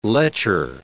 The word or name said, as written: lecher